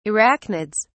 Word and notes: arachnids: British English

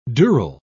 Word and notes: dural: British English

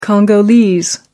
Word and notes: congolese: American English

